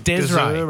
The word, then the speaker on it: Desree: No, but it's spelt D-E-S-R-E-E. So it's Desray.